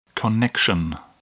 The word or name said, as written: connection